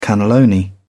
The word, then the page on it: cannelloni: British English